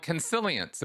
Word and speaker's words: Consilience: And you are the author of a book called Consilience. Am I pronouncing that right? – Yep.